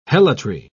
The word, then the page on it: helotry: British English